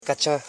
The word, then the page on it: kacha: ka.cha